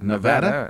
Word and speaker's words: Nevada: You see, in every state's got its idiosyncrasies, and Missouri doesn't pronounce any town the way it's supposed to be pronounced. – Well, what did she say? – Nevada. That's the correct way to pronounce it. It's spelled just like the state of Nevada.